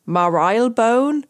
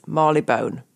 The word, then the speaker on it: Marylebone: It's not Marylebone, it's not Marylebone, it is Marleybone.